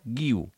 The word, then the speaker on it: Guiu: I es lien, s'emboliquen a adaptar un nom que no necessita adaptació. Guiu. G-U-I-U. Guiu. Com deia aquell senyor.